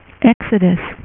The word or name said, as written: Exodus